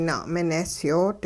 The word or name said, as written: na'minesyoot